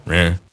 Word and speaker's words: Reims: and then later in Reims, or Reims, if you're pronouncing it in French.